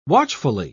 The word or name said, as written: watchfully